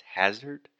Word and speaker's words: hazard: I believe Alexia said hazard or something like that. So that first sound is the ah. Just like dad, master, disaster. So the correct pronunciation is hazard.